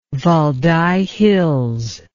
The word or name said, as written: Valdai Hills